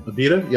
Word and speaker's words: Abita: I'm going to retire Abita? Is that how you say it? – Abita, yeah.